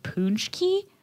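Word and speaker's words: pączki: And she said she thinks it's pronounced poonchki.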